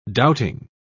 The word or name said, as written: doubting